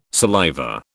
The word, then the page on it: saliva: British English